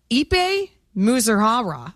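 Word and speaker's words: Ippei Mizuhara: On paper, Ipe Muzuhara.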